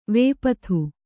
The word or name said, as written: ವಿಪತ್ತಿ